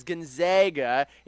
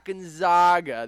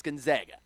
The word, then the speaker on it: Gonzaga: It is Gonzaga. It is not Gonzaga. There's no long A. So pronunciations being what they are, let's all go with the same thing. It's Gonzaga.